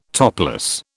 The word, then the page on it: topless: British English